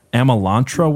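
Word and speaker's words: Amalantrah: And that's because in 1918, Aleister Crowley did this thing called and tell me if I'm pronouncing this wrong, the Amalantra working. Does that sound correct? – That's how I pronounce it.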